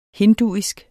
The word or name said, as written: hinduisk